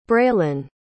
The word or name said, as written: braelyn